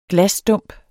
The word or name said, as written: glasstump